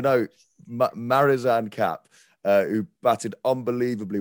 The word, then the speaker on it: Marizanne: now I never say her name correctly, but it's why. – well, no, Marizanne Kapp. Yes.